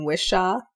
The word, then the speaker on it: Whishaw: So on here we have Ben Whishaw.